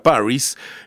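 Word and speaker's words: PVRIS: cambiaron la A por una V, pero se pronuncia Paris,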